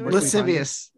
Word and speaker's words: lascivious: I'm just so lascivious is how you pronounce it, according to Google.